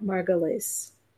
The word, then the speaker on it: Margulies: I'm here joined today with Rabbi Ariel and Chana Margulies.